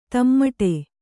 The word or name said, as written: ದಮ್ಮಡಿ